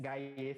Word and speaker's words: Gaiye: Gaie se escreve G-A-I-Y-E, ou seja, arroba G-A-I-Y-E, G-A-I-Y-E.